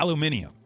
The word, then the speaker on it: aluminium: Aluminum. These are the words you can't pronounce. Oh, aluminum. Yeah. Aluminium. – Mm-hmm. I have heard people say that in Really? – I think in Britain they might say that.